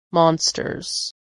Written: monsters

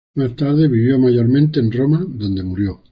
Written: mayormente

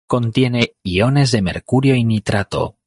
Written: mercurio

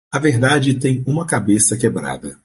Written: quebrada

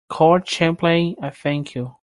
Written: Court

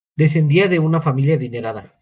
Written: adinerada

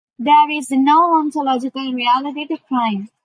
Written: ontological

